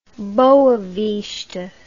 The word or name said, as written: Boa Vista